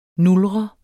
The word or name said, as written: nulre